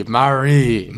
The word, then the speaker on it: Maree: It's spelled M-A-R-E-E. Mari.